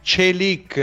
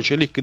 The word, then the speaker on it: Çelik: Sto leggendo già che con l'arrivo di Çelik, attenzione, Çelik, diceva bene il direttore, eh? Poi questa sera mi faccio registrare da un collega turco, mi faccio registrare la pronuncia esatta, ma è Çelik, io me lo ricordo bene, il nostro collega amico Dundar Kesapli, che intervenne peraltro una volta anche a Radio Bianconera.